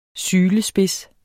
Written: sylespids

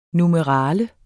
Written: NUMERALIER

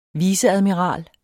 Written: viceadmiral

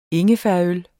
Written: ingefærøl

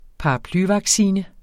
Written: paraplyvaccine

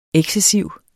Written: excessiv

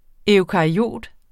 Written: eukaryot

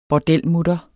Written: bordelmutter

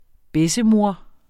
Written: bessemor

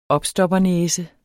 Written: opstoppernæse

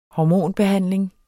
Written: hormonbehandling

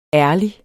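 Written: ÆRLIGST

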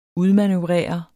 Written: udmanøvrere

